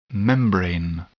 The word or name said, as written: membrane